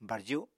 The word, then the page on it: barju: Apatani